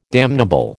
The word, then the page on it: damnable: American English